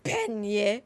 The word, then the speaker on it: panniers: P-A-N-N-I-E-R-S. – Panier. Probably. You wouldn't pronounce the hard S at the end.